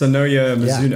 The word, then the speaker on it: Sonoya Mizuno: How do you pronounce her name? I'm going to hand that one over to you. – It's Sonoya Mizuno.